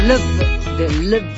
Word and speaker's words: Louvre: De Louvre, así se pronuncia.